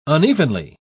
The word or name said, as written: unevenly